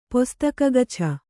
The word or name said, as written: ಪೊಸ್ತಕಗಛ